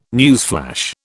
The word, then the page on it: newsflash: British English